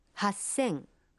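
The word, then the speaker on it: Хасэн (八千): Но и здесь произношение меняется. 3000 будет звучать как? – Сэнзэн. – 8000? – Хасэн.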